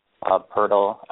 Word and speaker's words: Poeltl: Those 2 7-footers, Jakob Poeltl.